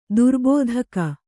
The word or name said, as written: ದುರ್ಬೋಧಕ